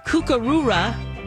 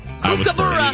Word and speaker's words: Kookaburra: Okay, were they called Koala, Kookaroora, or the Crackle Patty? – Kookaroora! – Kookaroora! Burra! – Kookaburra!